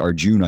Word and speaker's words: Arjun: And you know, just where I come from, I'm a huge fan of the battle epic in which Arjun, which has A at the end, but it's pronounced Arjun, I believe, is being counseled or advised.